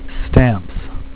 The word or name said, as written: stamps